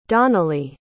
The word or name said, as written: donnally